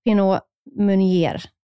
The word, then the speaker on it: Pinot Meunier: Och sen har vi Pinot Meunier.